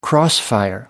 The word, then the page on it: crossfire: American English